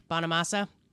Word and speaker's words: Bonamassa: Bonamassa.